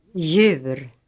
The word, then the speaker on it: djur: The letter J is sometimes preceded by another consonant in the writing system. In such cases, the other letter is always silent. Examples. – Jude. Djur. Hjälp. Ljus.